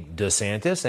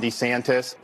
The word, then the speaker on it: DeSantis: How do you say the name DeSantis? Apparently, some people like her say DeSantis, and, well, Ron says it, Governor Ron DeSantis says it differently. – I am Ron DeSantis.